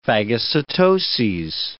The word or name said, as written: phagocytoses